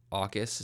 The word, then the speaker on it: AUKUS: Notable is that AUKUS, is how you pronounce it, nuclear deal that Australia made with the States and the UK, largely, I think, to counter China's influence in the region.